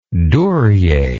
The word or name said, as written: duryea